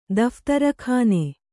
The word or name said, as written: ದಫ್ತರಖಾನೆ